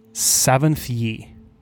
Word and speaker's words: Saventh-Yhi: And the word is Saventh-Yhi. – Oh, I see. It's a Paizo word. – Yes, it is. – Thanks, Paizo. – Uh, so it is spelled S A V E N T H dash. – Yep. Y H I.